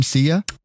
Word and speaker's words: Sia: Sia.